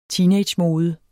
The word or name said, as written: teenagemode